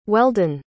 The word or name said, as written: Weldon